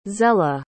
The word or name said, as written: zella